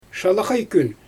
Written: ʃalaxaj kyn

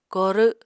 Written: korw

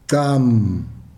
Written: xam

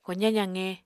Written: kozañ zaqe

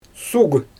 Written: suuɣ

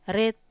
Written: ret

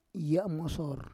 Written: yamosor

